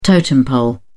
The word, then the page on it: totem pole: British English